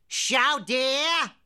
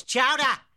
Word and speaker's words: chowder: Chow dear. Chow dear. It's chowder. Say it right.